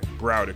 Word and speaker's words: Browder: Kalief Browder. Broder. What's his name? Browder. Browder. Kalief Browder.